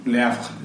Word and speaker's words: Le Havre: Le Havre.